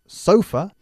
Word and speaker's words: sofa: Fijaros que el énfasis es diferente. Nosotros decimos sofa y vosotros sofá. Exagerando, por supuesto, pero nosotros acentuamos la primera sílaba.